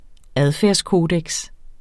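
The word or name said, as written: ADFÆRDSKODEKSEN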